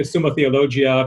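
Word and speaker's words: Summa Theologica: Of course, I don't know what Thomas would have said, but I say Summa Theologia. If you want to pronounce the C, I won't hold it against you, I promise.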